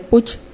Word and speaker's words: Puig: Empresa se dice Puch, se escribe Puig.